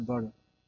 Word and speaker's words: bora: and that's a bora. D is not pronounced like a D.